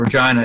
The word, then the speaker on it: Regina: But the joke about Regina is it's the city that rhymes with fun.